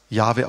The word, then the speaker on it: Jahwe: Im Hebräischen steht dann in der Bibel JHWH, was Jahwe ausgesprochen wird.